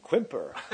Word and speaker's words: Quimper: Say, could you spell that so we could put it in our box here? Roger, it is Quebec Uniform India Mike Papa Echo Romeo. Ah, you mean Quimper.